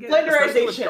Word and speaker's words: Flanderization: Flandersification, is that how you say it? – Yes. – Flanderization, I think. – Flanderization.